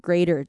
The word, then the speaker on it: greater: 然后后面的 their fourth generation or greater 都没有太大的问题啊，greater 的那个部分就把它念成 der der der greater 这样就可以了。